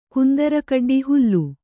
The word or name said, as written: ಕುಂದರಕಡ್ಡಿ ಹುಲ್ಲು